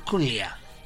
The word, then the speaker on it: Culea: Como como Carla Civil es profesora de inglés, me dice, ¿cómo se pronuncia? Me dijo, Culea. Ah, y entonces se llama Melinda Culea.